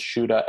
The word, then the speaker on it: Shuda: Sure, it's a D-A-Y-N-E Dane, and then the last name is Shuda, S-H-U-D-A.